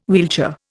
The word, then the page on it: wheelchair: Indian